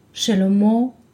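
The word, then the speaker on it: Shlomo (שלמה): But since there is a sound of Cholam, the sound of Cholam is O, up the letter Mem, so the word sounds like Shlomo with Vav.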